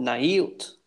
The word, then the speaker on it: naivt: Remember, the V is usually like a W sound, W yeah. sound. So this is kind of weird to say it, but naivt.